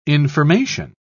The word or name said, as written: information